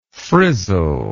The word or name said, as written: frizzler